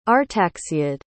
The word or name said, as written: artaxiad